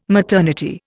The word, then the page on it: maternity: Indian